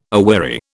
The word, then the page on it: aweary: British English